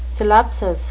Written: syllepsis

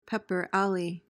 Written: pepper alley

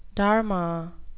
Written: dharma

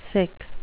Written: sic

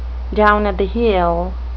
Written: down-at-the-heel

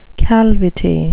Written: calvity

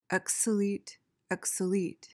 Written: exolete

